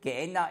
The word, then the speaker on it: Gehenna: Gehenna ist einfach griechisch, so ein bisschen griechisch ausgesprochen, das hebräische Gehenna. Und das heißt Tal Hinnom. Gehenna oder Gehinnom. Das ist ein Tal außerhalb der Altstadt von Jerusalem.